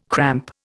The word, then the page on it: cramp: Indian